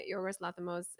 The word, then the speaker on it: Yorgos Lanthimos: Can I tell you, explaining Yorgos Lanthimos, is that how you say his name? – Yeah.